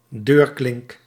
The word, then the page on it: poignée: France; /pwa.ɲe/